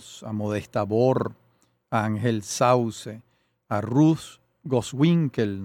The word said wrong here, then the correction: Gosewinkel: no sé si se pronuncia Gosewinkel. – Así, Gosewinkel, y Alberto Grau.